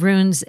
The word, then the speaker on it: runes: Now, talk about a little about the ancient wisdom of runes. And am I saying that correctly? It's R-U-N-E-S. – Yep. – Runes. That's what I thought.